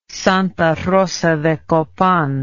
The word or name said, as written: Santa Rosa de Copán